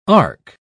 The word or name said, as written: arc